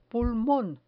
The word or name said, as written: pulmon